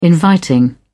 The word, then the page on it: inviting: British English